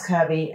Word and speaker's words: Kirby: Well, a little cachet, but then it's only butchered when it translates as in, because I say like, hi, my name's Kirby, and you know, I get Cubby.